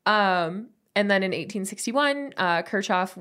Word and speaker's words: Kirchhoff: Kirchhoff, I think is how you pronounce his name. – Kirchhoff.